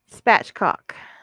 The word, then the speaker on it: spatchcock: Am I saying it wrong? Spatchcock. There's no L.